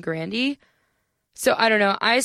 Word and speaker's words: Grande: but she she pronounced it Grandy, not Grande, Grandy.